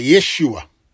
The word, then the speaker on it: Yeshua: Not with a Y, but with an I, Yeshua. That's how I have learned to pronounce it.